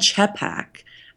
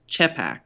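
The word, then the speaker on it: trzepak: And they're called Czepak. And I'm not pronouncing that completely right, but it's T-R-Z-E-P-A-K, Czepak.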